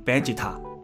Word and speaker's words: Vegeta: 那日文的 v 是发 b 这样的音嘛，就是所以就读成 vegeta，这样的一个发音就变成贝吉塔了，就类似这样的意思。